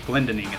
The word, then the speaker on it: Glendening: We've also got Luke Lindling, and he is... I believe that's how you pronounce his name, Glendening. Glendening is how you say it. I think I was trying to add an L in there.